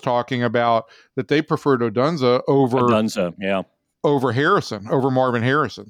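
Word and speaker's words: Odunze: I thought, is it Odunza? How do you pronounce it? Yeah, Rom Odunza, yeah.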